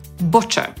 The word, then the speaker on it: butcher: Podsumowując, słowem, z którym wielu Polaków ma problem, bo wymawiają je niepoprawnie, jest rzeźnik, czyli po angielsku butcher. Wymawiamy go przez u, a nie przez a.